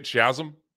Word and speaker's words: Shazam: Is that how you say it? Shazam? – Shazam.